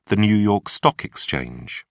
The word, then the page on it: new york stock exchange: British English